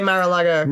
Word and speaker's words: Mar-a-Lago: Am I saying that right? – Yeah, Mar-a-Lago.